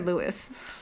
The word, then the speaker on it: Luis: Louis, is his name Louis or Louis? – They call him Louis, but his name is spelled L-U-I-S.